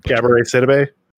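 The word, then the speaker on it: Gabourey Sidibe: Gabori Sabib. Oh man, Gabori Sidibe. – Yeah, I'm sorry. I'm awful. I butchered it.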